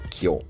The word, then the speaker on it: Kyo: En Japón, los caracteres chinos para flor del loto se pronuncian Renge, y el carácter para la enseñanza del Buda o un sutra se pronuncia Kyo.